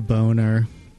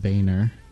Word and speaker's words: Boehner: Oh, Boner. I think it's pronounced Boehner. B-O-E-H-N-E-R.